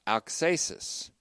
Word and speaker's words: auxesis: In rhetoric, it's called auxesis, A-U-X, long E-S-I-S, auxesis.